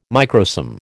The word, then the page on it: microsome: American English